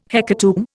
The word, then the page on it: hecatomb: Indian